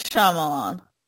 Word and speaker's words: Shyamalan: Is it Shyamalan? – M. Night Shyamalan.